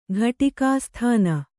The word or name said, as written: ಘಟಿಕಾಸ್ಥಾನ